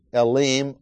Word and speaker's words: Elim: And if you're reading Old Testament, whenever you find a word, especially a noun of a person's name or a place, and it ends in I-M, or if it ends in I-N, always pronounce it Elim, or, as we'll find, the wilderness of Sin. So, verse 1, then they set out from Elim. Im is Hebrew plural.